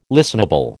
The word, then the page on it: listenable: American English